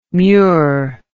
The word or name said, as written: mure